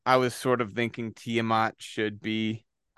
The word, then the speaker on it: Tiamat: Number one was Tiamat. Is that how you say it? – That's how I say it. – Yeah. Tiamat.